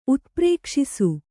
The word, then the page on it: ಉತ್ಪ್ರೇಕ್ಷಿಸು: utprēkṣisu